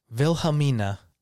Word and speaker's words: Wilhelmina: Wilhelmina. – What? – Wilhelm, like the German name Wilhelm. Uh-huh. Mina.